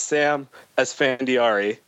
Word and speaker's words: Sam Esfandiari: It's Sam Esfandiari.